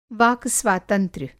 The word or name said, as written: वाक-स्वातंत्र्य